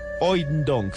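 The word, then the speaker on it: Hoeydonck: Es una obra de un artista belga que se llama Paul Van, escuchen muy bien, es que es difícil de pronunciar para los que hablamos español, Oindonk, se escribe H-O-E-Y-Donk, terminando con C-K que eh, conoció un astronauta que se llamaba David Scott cuando estaban comiendo.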